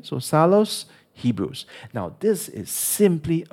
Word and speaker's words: Psallos: you must listen to Psallos.